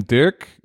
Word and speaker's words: Dirk: I should say Dirk, right? I say Dirk, like with an English accent, I guess.